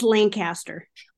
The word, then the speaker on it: Lancaster: How do you pronounce the Lancaster out there? I just pronounced it the Pennsylvania way. – It's Lancaster.